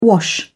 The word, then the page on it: wash: British English